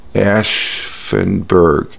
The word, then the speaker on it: Aschaffenburg: I don't think I'm pronouncing it. It's Aschaffenburg.